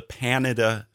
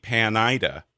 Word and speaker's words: Panida: I messed it up for years because it's kind of short for Panhandle of Idaho, but it would be Panida, and it's not that either. It's Panita,